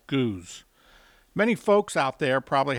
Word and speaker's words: Gueuze: the barrel-aged and blended style of Belgian lambic known as Goose. That's spelled G-U-E-U-Z-E, and is the French name for the style.